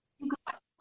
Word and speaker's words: Quaternary: Now, am I saying this right? Quaternary twins. How do we say it? Quaternary. – Quaternary. quaternary twins. Yes. – Sounds right.